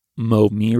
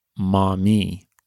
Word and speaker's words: Maumee: But I said Mo-Me River. And thank you to Sean in Ohio, who let us know that it's Maumee River. And again, I've probably still pronounced it incorrectly. My best attempt there, Sean. And thank you again for bringing this to our attention. My French, I think, weighed in on that M-A-U. A-U in French is O.